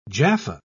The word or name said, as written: jaffa